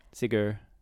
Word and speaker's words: Sigur: I will like Sigur Ross. Sigur. – It's S-I-G-U-R. – Yep, Sigur, yeah.